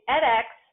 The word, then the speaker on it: edX: Am I saying that right? Or is it Ed Extension? I realized when I introduced you, I didn't ask. – No, that's okay. So it's also a little weird. It's EdX, but as I was going through the interview process, they got acquired by 2U.